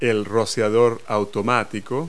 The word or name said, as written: sprinkle